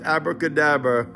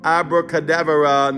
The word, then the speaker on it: abracadabra: Yes, this is the phrase commonly mispronounced as abracadabra. the phrase should be pronounced abracadabra.